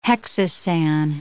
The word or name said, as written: hexosan